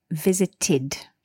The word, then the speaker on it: visited: The ed here is pronounced id, visited.